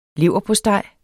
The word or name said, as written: leverpostej